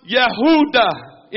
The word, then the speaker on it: Yehuda: The name Judah means to celebrate with praise. It's pronounced Yehuda in Hebrew.